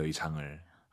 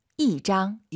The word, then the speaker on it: 一张: 숫자 1이 여러분 왜 1성이 아니라 4성인 줄 아세요? 뒤에 짱이 1성이잖아요. 숫자 1 뒤에 1성이면 숫자 1이 4성으로 바뀝니다. 그래서 이장 이게 아니라 이장. – 이장 이렇게 발음하시기 바랍니다.